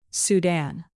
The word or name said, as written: sudan